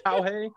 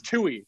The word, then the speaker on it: Tuohy: Sometimes it's Tuohy, sometimes it's Tauhey. The pronunciation is Tuohy, Tuohy, like Ratatouille.